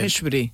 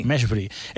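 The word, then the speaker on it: Mejbri: Eh, Meshbri. Eh, está buscando, se pronuncia más o menos. Meshbri.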